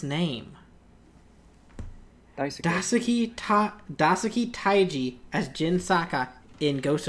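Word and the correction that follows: Tsuji: Suji, thank you.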